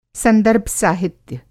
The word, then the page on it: संदर्भ-साहित्य: Hindi